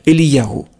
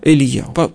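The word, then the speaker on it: Элияху (אליהו): Нет, Илья, ну просто и сами евреи его произносят чуть-чуть по-другому. Они его произносят Элияху. – Угу. – Элияху.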